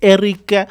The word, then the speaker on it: Eric: On Facebook, it's Eric Photos. Eric, I'm going to spell it E-R-I-C. E-R-I-C. That's the type of my name. You spell it like that. E-R-I-C.